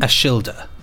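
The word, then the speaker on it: Ashildr: Ashildir.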